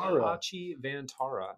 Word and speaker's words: Hitachi Vantara: We have Hitachi Vantara. Am I saying that right? – Hitachi Vantara.